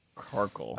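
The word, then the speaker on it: Carkol: Carkle.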